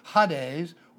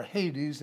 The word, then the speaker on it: Hades: Hades, or Hades, as many pronounce it, is the same location.